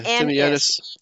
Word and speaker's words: Timianus: I pronounce it like I split it up into three syllables. – Right. – Tim, and then A-Y-E like I, yeah. – Or Tim A. – Yeah, Timianus. Timianus.